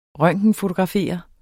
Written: røntgenfotografere

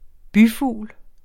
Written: byfugl

